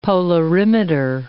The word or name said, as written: polarimeter